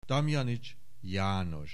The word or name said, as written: Damjanich János